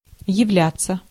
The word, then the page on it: являться: /jɪvˈlʲætt͡sə/